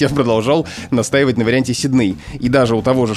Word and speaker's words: Сидней: Это название австралийского города Сидней, которое, согласно словарям, и в том числе словарю трудностей для работников в СМИ Михаила Абрамовича Штудинера, произносится в образцовой речи именно так — Сидней. Но есть ряд людей, и это очень популярная тенденция, говорить «Сидней». Ну, потому что так говорят же в оригинале «Сидней», в английском говорят «Сидней».